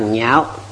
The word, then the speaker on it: nhạo: Chỗ này đọc là nhạo, tức là anh ưa thích, anh cảm thấy điều này rất vui thích.